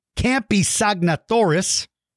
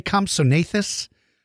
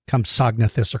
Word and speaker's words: Compsognathus: Now, if somebody pronounces its name Campsognathus, that's wrong, because there's no Campsognathus. But if you pronounce it Compsognathus, Compsognathus, or Compsognathus, there are different ways you can pronounce it. But I will tell you the truth. I always say Compsognathus, but most of the scientists that I know usually pronounce the G, Compsognathus or Compsognathus.